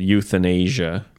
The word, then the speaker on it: euthanasia: And it doesn't mean young people in Asia. – Youth in Asia, yeah. Yeah. No, it's spelled completely different. – Very different. – E-U-T-H. It's a tricky, euthanasia. – Tricky word. – E-U-T-H-A-N-A-S-I-A, I think. Euthanasia.